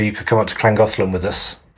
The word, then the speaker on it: Llangollen: You could come up to Llangollen with us. – Don't be rude. – I'm not. – Okay. – That's how the Welshies pronounce it.